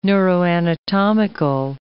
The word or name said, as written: neuroanatomical